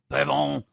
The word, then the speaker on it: Pavon: Pavon.